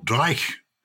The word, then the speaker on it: dreich: Dreich, am I pronouncing it correctly? – Yes, you are. And I think that is often chosen as the Scots' favourite word. – D-R-E-I-C-H.